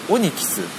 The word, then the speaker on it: ONYX: このシステムのキャッシュをクリアするためのフリーウェア、前回ご紹介して読み方が分かんないよっていうことを言ったら、 Twitter で会員の長野さんがオニキスと読むようですと、教えていただきました。 ONYX でオニキスなんですね。